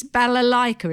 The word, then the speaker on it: balalaika: And a bass balalaika. Is that how you pronounce it? – Yeah.